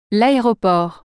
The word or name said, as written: aeroport